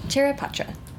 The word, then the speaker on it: Charaipotra: Charaipotra is a beautiful name, and I did not want to butcher it.